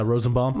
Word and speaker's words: Rosenbaum: I got to glimpse at Michael Rosenblum. How do you pronounce it? Rosenblum? Rosenbaum? – Yeah, Rosenbaum. – Rosenbaum.